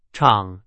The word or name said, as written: 厂